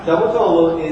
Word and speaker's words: Davutoğlu: When you see that mark, it means that G's silent. So you don't say it. So this is how you say it. I just said So Davutoğlu is Turkey's foreign minister.